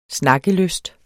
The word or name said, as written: snakkelyst